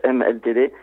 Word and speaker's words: HMLTD: Il y a la prononciation actuelle qui est HMLTD et euh,